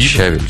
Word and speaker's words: щавель: Ладно, да разве щавель, щавель? Вдруг. – Да по-разному говорят. – Да? Ну вот у меня всегда говорят щавель, но, по-моему, правильно говорить щавель.